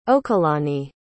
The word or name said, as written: okelani